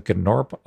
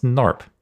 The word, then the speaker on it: Gnorp: And he's like, yeah, so the Gnorp Opalog. And I'm like, Gnorp? Really? That's how you're going to pronounce that? No, it's Norp. Come on. Nobody's going to pronounce the G.